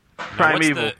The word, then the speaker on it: Primeval: Primeval.